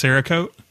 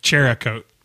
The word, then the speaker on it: Cerakote: The IG Militia said he's heard a lot of people pronounce Cerakote, Cherakote. – Yeah.